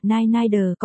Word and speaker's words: NND: Phát âm là Nai Nai Đờ có nghĩa là mụ nội nó.